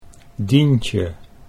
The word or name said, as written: Dientje